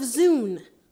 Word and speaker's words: Zune: The Zone of Zune? – No, it's the Zone of Zune. – That's what I said. – No, Oliver. You pronounced Zune with a U. It's actually pronounced with a double O.